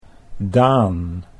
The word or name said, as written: Daan